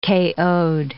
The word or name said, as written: KO'd